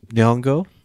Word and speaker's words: Nyong'o: Us is directed and written by Jordan Peele, the man behind Get Out, starring Lupita Nyong'o. Is that how you pronounce her last name? Nyong'o, right? – Yeah, that's close.